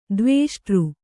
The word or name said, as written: ದ್ವಿಷ್ಠ